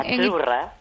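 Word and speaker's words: Azzurra: Achurra. – Achurra. Mira, ¿ves? – Achurra. Achurra. Sí, como porque pizza, la Z no es la Z como decimos en catalán tan sonora, no.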